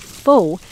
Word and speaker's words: pho: It's spelled P-H-O, pho, but pronounced pho.